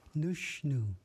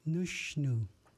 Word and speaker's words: Nushnu: In Ichishkin or Warm Springs, nose is said Nushnu. Nushnu.